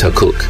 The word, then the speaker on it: Takkuuk: One project that many listeners may know about involved the amazing electronic duo Bicep, who collaborated with indigenous artists on a project called Takuk, spelt T-A-K-K-U-U-K, and pronounced Takuk.